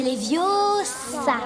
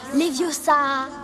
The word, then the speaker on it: Leviosa: C'est Leviosa. Et pas Leviosa.